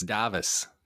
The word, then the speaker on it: Davis: Can't it just be Davis? What is it supposed to be? – No, it's Davis.